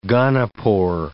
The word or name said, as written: gonopore